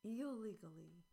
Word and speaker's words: illegally: Challenging word for many speakers. Not eel, but ill. Eel, ill, ill. Ill-ly, ill-ly. Ill-legally, illegally, illegally. Second syllable is longer and stronger. It is the most stressed syllable.